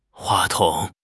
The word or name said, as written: 话筒